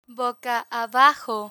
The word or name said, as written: boca abajo